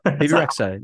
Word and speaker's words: Bebe Rexha: Is it Bebe Rexha? – Bebe Rexha, you got it.